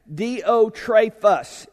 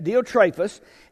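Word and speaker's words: Diotrephes: Diotrephus, I looked up the way you pronounce that. Diotrephus is the long pronunciation of that. Diotrephus, and then Demetrius, two other men, along with Gaius.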